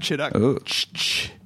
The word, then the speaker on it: Chituc: We have the next best thing, or the better thing, which is Vlad Chituc. – Chituc, like chicken tuck. No one in the history of the universe has ever pronounced my name right.